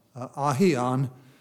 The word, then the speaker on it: aion: aion, I guess is the way it's pronounced, A-I-O-N.